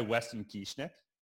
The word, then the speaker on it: Weston Kieschnick: I was reading this tweet by Weston Kieschnick. – Oh yeah. – Did you hear how I hammered? Like just nailed that one. – That was good. That pronunciation was really good.